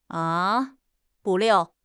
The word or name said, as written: 不料